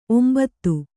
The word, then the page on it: ಒಂಬತು: ombatu